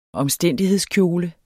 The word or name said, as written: omstændighedskjole